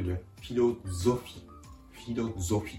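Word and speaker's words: philosophie: 続けて発音すると、フィロゾフィ、フィロゾフィです。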